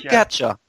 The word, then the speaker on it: gacha: Or, I guess, is it gacha or gacha? – It's a gacha. Gotcha. – Okay, I didn't know how to pronounce it because I always I just think of it as a gacha game. we hook you, we gotcha now. – It comes from gachapon.